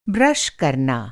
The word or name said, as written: ब्रश